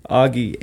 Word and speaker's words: Augie: Augie. It's one G. Augie. A-U-G-I-E.